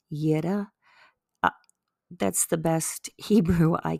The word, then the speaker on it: Yireh: And then the Yireh is Y-I-R-E-H, and it means the Lord will provide.